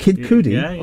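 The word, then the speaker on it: Kid Cudi: Kid Coody.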